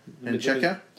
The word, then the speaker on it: Menchaca: Men Menchaca. – Menchaca. – Menchaca. What's show me it again? Let me see what that is. – Menchaca. – Menchaca.